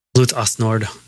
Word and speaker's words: Blut aus Nord: Blut aus Nord. – Blut aus Nord.